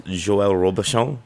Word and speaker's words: Joël Robuchon: A side note, did I pronounce Joël Robuchon right? – You did very well.